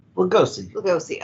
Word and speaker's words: Lugosi: Lugosi.